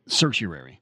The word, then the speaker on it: certiorari: In fact, the vast majority of petitions for writ of certiorari, I looked it up, man. – Okay, what is it? – Certiorari.